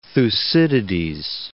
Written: Thucydides

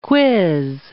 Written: quiz

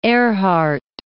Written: Earhart

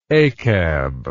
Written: achab

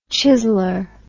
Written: chizzler

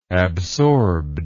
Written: absorbedness

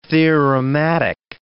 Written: theorematic

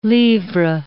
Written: livre

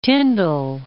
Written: Tyndall, Mount

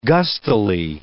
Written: gustily

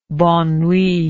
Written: bonne nuit

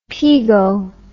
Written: pego

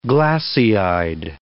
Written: glassy-eyed

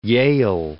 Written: Yale

